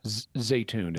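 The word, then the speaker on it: Zeitoun: Zaytun is the local pronunciation.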